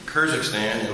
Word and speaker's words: Kyrgyzstan: Since Ms. Cheryl and I returned to Kyrgyzstan, am I saying that right? Yeah. Kyrgyzstan in late June, we have been working on our visas.